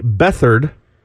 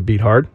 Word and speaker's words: Beathard: C.J. Beathard, if you want to pronounce it right. I like to say Beathard.